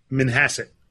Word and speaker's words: Manhasset: Manhasset.